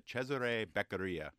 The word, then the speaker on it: Cesare Beccaria: Well, I've been to Italy recently, and I've heard that people pronounce it Cesare Beccaria, at least in Italy. But I think Italian names have wide variation of pronunciations in the United States.